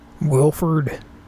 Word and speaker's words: Wilford: Wilford. – W-I-L-F-O-R-D.